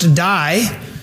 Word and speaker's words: dei: These words, it was necessary, are actually one word in the Greek, spelled D-E-I, but it actually is pronounced die.